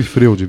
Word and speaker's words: Freud: Então, assim, se escreve Freud, viu, gente? Só que se fala Freud, tá?